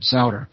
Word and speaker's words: Sauder: And, of course, I'm Richard Sauder. Mm-hmm. My last name is spelled S-A-U-D-E-R.